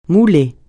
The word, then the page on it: mouler: francais